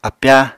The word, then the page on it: apyáa: Apatani